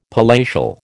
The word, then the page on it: palatial: American English